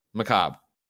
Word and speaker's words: macabre: Macabre. Jen's yelling at me in French. – The R-E is silent? – Yeah. – Macabre.